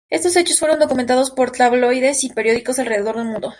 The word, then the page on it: documentados: ispanu